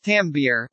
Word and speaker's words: timbre: In music theory, timbre, pronounced tambir, refers to the sound quality or tone quality of a note on a musical instrument.